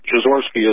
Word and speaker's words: Jeziorski: It's, the I is silent. Jeziorski is the pronunciation.